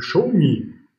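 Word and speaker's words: Xiaomi: Bitcoin steht vor dem Allzeithoch, Anhäuser Busch ist ein Thema und Show Me richtig ausgesprochen, am Automarkt.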